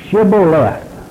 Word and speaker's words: Shibboleth: Shibboleth. This is pronounced like an E instead of an I.